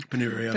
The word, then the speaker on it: Ponnuru: Uh, so it's a retweet of Ramesh Ponnuru. Is that how you say his name? Ponnuru.